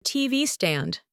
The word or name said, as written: TV stand